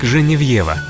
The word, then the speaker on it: Женевьева: Святая Геновефа более известна нам как Женевьева. Так произносят ее имя на родине во Франции.